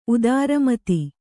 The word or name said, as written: ಉದಾರಮತಿ